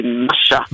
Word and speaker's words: Masha: When I was writing the book, I was saying in my very flat Australian accent, I was calling her Masha. – Mm-hmm. – But then Nicole Kidman, who has already bought the rights and who will hopefully play this role, she corrected my pronunciation with a beautiful Russian accent and said, no, it would be Masha.